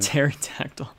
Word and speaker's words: Pterodactyl: Pterodactyl. Silent P.